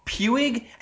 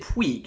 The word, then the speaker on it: Puig: And you're actually right, because I just assumed that his last name was pronounced Puig, and you said Puig, and I just figure, oh, it's, you know, Sarah's pronouncing. I'm sure it's wrong. And you're right.